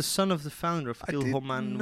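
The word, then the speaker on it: Kilchoman: We're going to hear later on in the podcast from a guy called James Wills, who is an expert of Kilmaine. Did I say that right? – Kilmaine, yes.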